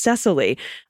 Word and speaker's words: Cecily: Felicity, pronouns she and her, D-A-G. And I think when you said Cicely Tyson, you meant Cicely Strong. Yes, I did that today again, too. Pronounced Cecily.